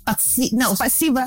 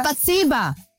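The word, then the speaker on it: Spasiba (спасибо): Spasiba. – Spasiba.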